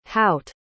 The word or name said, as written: hout